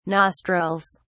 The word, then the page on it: nostrils: British English